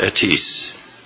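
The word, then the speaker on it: Etis (עץ): And the Hebrew word is, I don't speak Hebrew, I'm sorry about that, but anyway, it's spelled E-T-S, and it's pronounced Etis.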